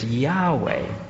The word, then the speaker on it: Yahweh: In the Bible, wherever you see the word Lord spelt in capital letters, it refers to his personal name, which in the Hebrew spells YHWH. And because there's no vowels in there, you don't know how to pronounce it. You know, is it Yahweh or something like that? But of course, we've anglicized it, put in a couple of vowels, and so the word is Yahweh.